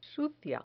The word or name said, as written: sucia